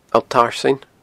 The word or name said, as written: Allt Tarsainn